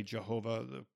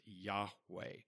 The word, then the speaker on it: Yahweh: And so, some would say Jehovah. We would pronounce that as Yahweh, Yud Hey Vav Hey.